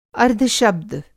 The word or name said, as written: अर्द्धशब्द